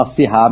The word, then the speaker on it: Syrah: Mas aqui a gente fala Syrah mesmo, tá? Ou Syrah.